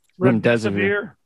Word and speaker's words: Remdesivir: So this whole thing about uh, how do you pronounce it? Was Desivir? Remdesivir. Remdesivir. Okay.